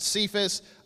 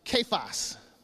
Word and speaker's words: Cephas: We pronounce it Cephas. Actually, it's pronounced Kephas in Aramaic, or Peter in Greek, which means stone in both languages.